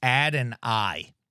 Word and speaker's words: Adonai: That's how you know how it say his name. It's Adonai. That's how it's like spelled. Adonai Mitchell.